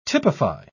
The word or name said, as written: typify